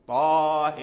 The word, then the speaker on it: طه: إذن فالنطق الصحيح والصواب هو طه طه، لأن طه هذه فيها ألفان، ألف الطاء طاء وألف الهاء.